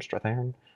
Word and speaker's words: Strathairn: Is that how you say his name, Strathairn? – Yeah, I believe so.